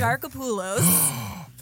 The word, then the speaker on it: Exarchopoulos: Adele Exarchopoulos.